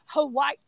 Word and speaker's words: Hawaii: Sorry. That's pronounced Hawaii.